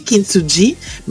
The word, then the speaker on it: Kintsugi: Alors, ça se lit Kintsugi, mais ça se prononce Kintsugi.